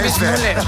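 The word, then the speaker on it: Weissmuller: Johnny Mismuller. Así lo pronunciamos.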